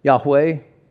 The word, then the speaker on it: Yahweh: And that God, whose name was Ihwah, what's God's name? How do we pronounce it? Yahweh, flip it around. It sounds pretty similar, doesn't it?